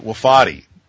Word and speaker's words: Wofati: Well, first of all, when I make up a word, I get to decide how it's pronounced. So I've elected to pronounce it Wofati.